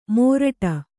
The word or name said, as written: ಮೋರಟ